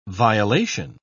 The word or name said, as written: violation